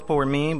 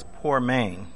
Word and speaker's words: poimen: The third Greek word that may be, again, translated by the two English words is the word poimen, P-O-I-M-E-N. I thought it was poor mean, but I looked it up and it's poor main.